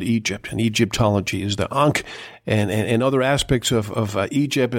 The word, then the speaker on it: Ankh: How do you pronounce it? Ankh? – Unks. – Unks. Yes. Okay.